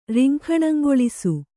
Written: ರಿಂಖಣಂಗೊಳಿಸು

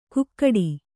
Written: ಕುಕ್ಕಟಿ

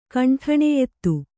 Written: ಖಂಡನೀಯತೆ

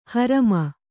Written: ಹರಾಮ

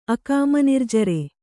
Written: ಅಕಾಮನಿರ್ಜರೆ

